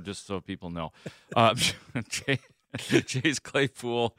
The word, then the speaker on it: Claypool: It's actually pronounced Claypool. – Claypool? – Yeah, yeah, that's it.